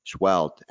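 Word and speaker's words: Seheult: It's S as in Sam, E-H-E-U-L-T, but I just learned the correct pronunciation is Dr. Schwelt.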